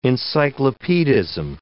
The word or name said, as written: encyclopedism